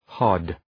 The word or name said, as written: hod